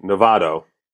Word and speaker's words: Novato: Uh, I know I'm overpronouncing it. You just pronounce it casually Novato. Uh, right. But when you say it too quickly, people think you're saying Nevada.